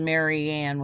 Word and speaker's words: Marianne: And, and so if you Google that, I have an, it's spelled Marianne with, it's one word with an I, and then Goldweber with one B.